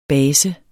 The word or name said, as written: base